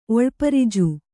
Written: ಒಳ್ಪರಿಜು

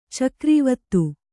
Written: ಚಕ್ರೀವತ್ತು

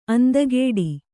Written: ಅಂದಗೆಡು

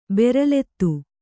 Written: ಬೆರಲೆತ್ತು

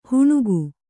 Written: ಹೆಣಿಗೆ

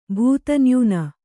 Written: ಭೂತನ್ಯೂನ

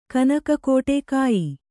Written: ಕಾಯಿ

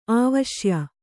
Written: ಆವಶ್ಯ

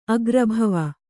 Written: ಅಗ್ರಭವ

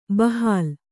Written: ಬಹಲ

